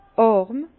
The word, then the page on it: àrm: Bas-Rhin; Herrlisheim